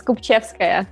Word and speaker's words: Kopivcheska: Olga Kopivcheska, I think I pronounced that right. Welcome to Crypto 101. – Thank you. Almost there. – Tell me, come on, the way you said it before sounded so beautiful, and then I just butchered it. – It's Kopivcheska. It's Russian, so...